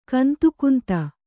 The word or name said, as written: ಕಂದುಕುಂದು